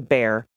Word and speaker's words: Bexar: First up, we got a lot of corrections for mispronouncing bear as Bexar County with a hard X.